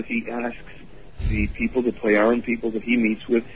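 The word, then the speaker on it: Plejaren: Pleiaran. – Pleiaran, okay. – Yeah, it's pronounced like a Y. – Okay, Pleiaran.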